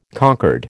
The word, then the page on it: concord: American English